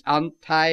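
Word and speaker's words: anti (ἀντί): You see, the word that he uses is a word pronounced anti, and of his fullness have we all received, and grace in place of grace.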